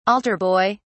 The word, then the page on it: altar boy: British English